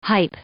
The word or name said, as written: hype